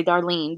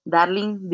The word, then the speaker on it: Darling: And I used to let it go, you know, like instead of darling, which is pretty American if you ask me, but they would say Darlene because they just couldn't believe that my name was Darling.